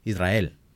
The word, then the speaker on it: Israel: So I'm like, Israel. And then I'm like... – You roll your R's. – Yeah, I roll my R's.